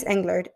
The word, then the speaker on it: Englert: Is played by Alice Englert. Or why am I pronouncing it French when she's American? Oh, Australian, actually. Oh, it's great. Alice Englert.